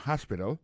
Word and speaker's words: hospital: L'hôpital, c'est comme ça, on dit ça en anglais, hospital. Alors, accent tombe sur le premier syllabe, il y a trois syllabes, puis she likes to visit hospital. Ensuite, elle a écrit H-O-S-P-I-T-A-L, hospital, l'hôpital.